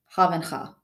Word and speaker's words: Havenga: Havenga, yeah. – Okay. You want to spell that? – His first name is J-U-A-N.